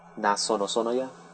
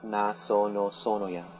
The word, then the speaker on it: Nazanoya: You can just call me Naz for short, N-A-Z. Naz is fine, that's what most people call me. But the correct pronunciation of my name is Nazanoya. Nazanoya. Or altogether, it's Nazanoya.